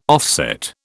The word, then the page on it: offset: British English